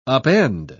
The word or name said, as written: upend